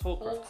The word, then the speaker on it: Horcrux: Horcrux. – It's Horcrux. – Horcrux. – So, me are gonna. – You pronounced Horcrux like Holocaust.